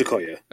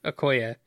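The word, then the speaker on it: Okoye: Is it Okoya? – I believe it's Okoya. – Okoya.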